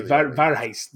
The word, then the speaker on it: Warheit: W like a V with German. – Okay, Warheit Zeurst.